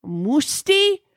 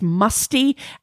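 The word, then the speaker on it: Mustii: Belgium has also announced their artist, and it's going to be, okay, somebody told me that his name is pronounced Musti. But I have to be honest, when I first read it, I read his name as Musty, and now I just have Musty stuck in my head and I cannot get it out.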